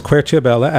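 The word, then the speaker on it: Querciabella: Well, if people would like to learn more or certainly follow you on Twitter, I'm going to spell it because we're pronouncing it the Italian ways. It's Quercibella, at Quercibella, Quercibella.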